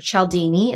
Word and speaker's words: Cialdini: So some of my favorites are Influence by Robert Cialdini, or Cialdini is how you pronounce it, and Chris Voss, Never Split the Difference.